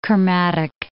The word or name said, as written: Kermadec Islands